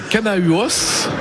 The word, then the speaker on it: Canahuos: Séverine, vous, votre entreprise c'est Canahuos, on prononce comme ça ? – Exactement. – Canahuos, et votre spécialité c'est la production de chanvre.